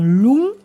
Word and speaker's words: Léon: Et enfin, Léon, par exemple, dont la prononciation actuelle est bien long, avec un N vélaire, qui atteste du fait que l'étymon est bien lungus, qui est un nom d'homme latin.